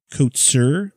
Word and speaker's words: Kotsur: Kotsur.